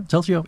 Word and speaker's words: Telzio: So yeah, we talked about beforehand your company, Telzio. Did I say it right? – Telzio? Mm-hmm. Yeah, Telzio.